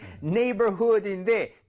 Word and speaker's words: neighborhood: 그렇기 때문에 네이버후드인데 GH가 들어갈 거 생각을 대부분 못 하기 때문에 당연한 실수입니다.